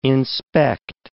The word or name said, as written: inspect